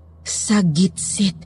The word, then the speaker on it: Sagitsit: Sa, sabit-sabit? No, Thelma. Sagitsit. Bigkasin mo ng tama.